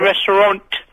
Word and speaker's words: restaurant: It's a restaurant. – In which country? – It's restaurant, Vinny. – No, restaurant has got a T at the end. – Yeah, but it's French. You don't pronounce the T.